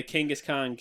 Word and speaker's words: Kangaskhan: I remember watching the last episode we did, which was, I got it here, The Kangaskhan Kid. I was just like, I said that wrong, didn't I? – No, you got it right.